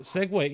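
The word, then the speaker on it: segue: Um, I it it never dawned on me that the UE is is pronounced Ohway uh, there. So Segway, yeah. So uh, basically the electric company failed me in in that one.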